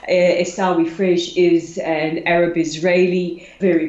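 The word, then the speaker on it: Esawi Frej: And I hope I pronounce his name right, Esawi Fredge. Why was this visit so significant, and who exactly is Esawi Fredge? – Well, first of all, I have to commend you on your pronunciation.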